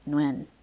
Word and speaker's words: Nguyen: One of my friends and I later scoffed at our supposedly more worldly college classmates who didn't know how to begin to pronounce the exceedingly common Vietnamese last name Nguyen, roughly Nguyen.